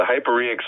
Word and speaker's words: hyporheic: Yeah, yeah, the hyporheic zone.